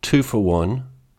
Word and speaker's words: Tufawon: two for one, spelt T U F A W O N but pronounced 241, is a Dakota Boricua hip hop artist from Minneapolis.